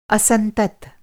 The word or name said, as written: असंतत